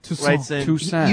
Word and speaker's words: Toussaint: So Toussaint. – Toussaint. – You screwed it. He just told you how to say it. – Eh? – Yes. – Toussaint. – The T is not pronounced.